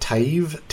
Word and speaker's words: Taiv: Taiv. It's T-A-I-V.